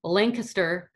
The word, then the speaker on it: Lancaster: It's in, I'm going to mess it, Lancaster, Pennsylvania. – No, you said it right.